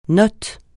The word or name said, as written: note